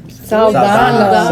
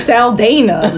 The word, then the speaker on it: Saldana: Saldana.